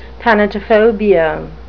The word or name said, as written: thanatophobia